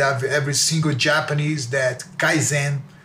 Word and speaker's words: Kaizen: In my business school, the Americans might have mispronounced it Kaizen, so Kaizen, huh? – Kaizen, which means like continuous improvement, yeah.